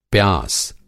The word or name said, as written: प्यास